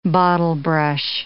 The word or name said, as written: bottlebrush